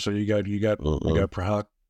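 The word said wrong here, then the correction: Procházka: How do you pronounce that again? – Yuri Prochazka. – yeah, that's it.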